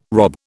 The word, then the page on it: rob: British English